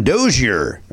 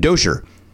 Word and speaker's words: Dozier: My name is Nathan Dozier. Oh, Kosher. Rhymes with Kosher. He tells us that. Nathan Dozier.